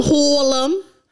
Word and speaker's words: Harlem: From Harlem. Emphasis on the H.